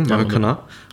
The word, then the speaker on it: Maracaná: Maracaná? – ah, Spanisch.